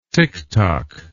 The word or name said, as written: tictoc